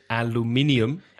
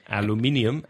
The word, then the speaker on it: aluminium: So there are five syllables in the British pronunciation of aluminium.